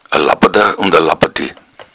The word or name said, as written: labbad